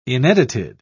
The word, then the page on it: inedited: British English